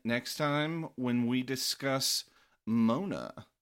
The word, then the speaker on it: Moana: M-O-N-A. – Like, oh, so Mona Lisa, not like Mona the Deftones song? M-O-A-N-A? – Exactly. – Moana? – Well, it's the Deftones song is pronounced Mona. Paula. What's the last name? – Oloiharuk. – Paula Oloiharuk? – O-L-O-I-X.